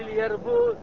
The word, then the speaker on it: اليربوع: وفي اليربوع جفرة. – وفي اليربوع 4 أشهر. – دويبة معروفة. بعض الناس يسميها الجاربوع بالجي. والبعض ينطقها بالياء. صحيح هذا وصحيح.